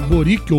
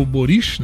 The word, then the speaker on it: Boric: O Boric ou Boris, né? A, a pronúncia dizem que é mais para Boris do que Boric, apesar que termina com um Czinho mudo, Ele né?